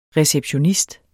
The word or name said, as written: RECEPTIONISTERNES